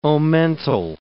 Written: omental

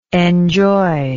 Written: enjoyingly

